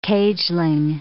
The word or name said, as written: cageling